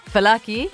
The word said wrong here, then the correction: fawaki: Fawaki. – Fawaki.